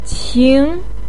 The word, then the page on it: qing: 2